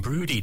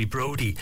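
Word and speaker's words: Broudie: I pronounced it wrong. It's actually Brody.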